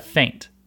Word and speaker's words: feint: In sword fighting, you sometimes use a move that's called a feint, okay? That's spelled F-E-I-N-T. And it sounds like fainting as in whenever you pass out, but it's spelled a little bit different.